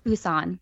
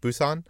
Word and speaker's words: Busan: Busan. – Busan.